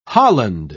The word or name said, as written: holland